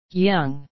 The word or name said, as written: young